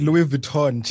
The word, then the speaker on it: Vuitton: It's Louis Vuitton.